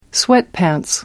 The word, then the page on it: sweatpants: American English